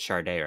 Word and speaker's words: Sade: You said Sade, right? – Yeah.